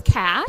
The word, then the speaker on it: CACH: So our acronym is CASH. Okay. So way easier than giving the whole long name. – And it's not spelled with an S, but with C-A-C-H.